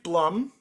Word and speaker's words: Blum: Uh, it, it's Steve Bloom. Steve Bloom is voice. Steve Blum is voicing. I think it's Blum is how you pronounce his last name.